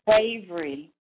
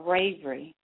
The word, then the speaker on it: Ravery: Ravery. Like Avery with an R. Ravery.